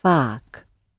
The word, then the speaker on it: vaak: And the word for often, oftentimes I think you say in America, is vaak. VAAK. So it's sort of like a far word, if you like.